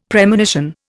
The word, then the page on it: Premonition: Indian